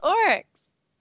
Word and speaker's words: Oryx: Did I say it like that? – We're just ragging you. – It's Oryx. Oryx?